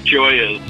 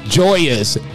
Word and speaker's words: Gioia's: Gio's. Am I pronouncing that right? – Gioia's. – Gioia's.